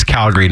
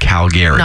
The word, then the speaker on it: Calgary: So, and if you're here for Stampede, by the way, it's Calgary, not Calgary.